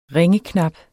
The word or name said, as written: ringeknap